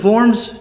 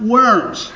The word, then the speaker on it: Worms: It was held in Worms, Germany. Note the German pronunciation of Worms. It is not Worms.